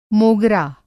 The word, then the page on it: मोगरा: Hindi